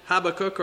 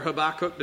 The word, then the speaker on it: Habakkuk: The prophet Habakkuk or Habakkuk, depending on your pronunciation.